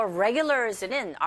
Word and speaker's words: regulars: 여기서 regulars는 R 사운드로 시작하는지라 첫 음 자체가 R일 경우에 우 하면서 준비를 하셔야 돼요. regulars, regulars. regular가 아니라 regulars 이렇게 발음하시고요.